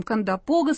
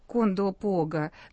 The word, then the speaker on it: Кондопога: Уважаемые слушатели, мы на «Свободе» говорим «Кандапога» с ударением на вот на этот слог. Кондопога на третий, а на центральных каналах с ударением на первый, и сами вот жители Кондопоги теперь говорят Кондопога, но вот кто как договорился, по словарям, судя по тому, что мы видим в словарях, Кондопога все-таки, вот договариваемся пока так.